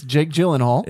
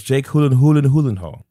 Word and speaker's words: Gyllenhaal: It's Jake Gyllenhaal. – It's Jake Hulahulahal.